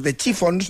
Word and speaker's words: The Chiffons: Años antes había triunfado otra creación, He's So Fine, compuesta por Ronald Mack e interpretada por The Chiffons, no sé si lo he dicho bien.